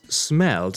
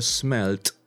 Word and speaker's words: smelled: The fox smelled the cheese. Smelled or smelt are both possible. In the first case, you write it with ed, in the second with t, and here you pronounce d or t.